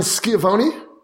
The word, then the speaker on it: Schiavone: Dan Schiavone.